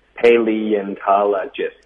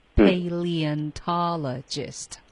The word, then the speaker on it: paleontologist: Paleontologist 六个音节。 – 哇、 wow, 嗯、六个音节、 Paleontologist。